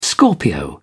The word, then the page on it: scorpio: British English